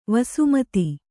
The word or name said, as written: ವಸುಮದ